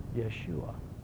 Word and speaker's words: Yeshua: Yeshua.